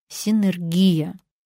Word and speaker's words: синергия: А вот с ударением бывают разногласия. Хочется подогнать его под привычную слуху энергию. Но есть только один правильный вариант – синергия.